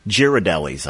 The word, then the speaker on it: Ghirardelli: Is me saying Ghirardelli correct? – Is it Ghirardelli or Ghirardelli? Yeah, I can't even pronounce it myself. Ghirardelli's, I thought. – Neither one. – Okay, which one? It is actually Ghirardelli.